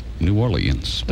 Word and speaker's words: New Orleans: Well, actually, that is the proper, Uptown St. Charles pronunciation. – What is the proper... – New Orleans. – New Orleans, okay. – The newscasters will say that.